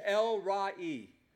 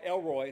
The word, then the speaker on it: El Roi: but the Hebrew pronunciation is El-Ra-I. I know some of you just like Elroy. No, that's George Jetson's son.